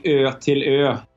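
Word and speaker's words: Ötillö: In Swedish, it would be Ertilö, which translates in English as island to island, which is the basic concept for that particular event.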